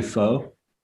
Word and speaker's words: pho: And I find that wonderful that you're giving them an opportunity to try it, because like am I pronouncing, is it pho or is it kind of like koi? Is it different? Like, oh, that was perfect. – No, yeah, it's actually pho.